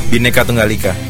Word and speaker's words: Bhinneka Tunggal Ika: Ah, Bhinneka Tunggal Ika.